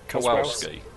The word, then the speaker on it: Kowalski: Kowalski.